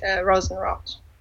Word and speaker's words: Rosenrot: Well, you can just say it, Rosenrot. With German R's.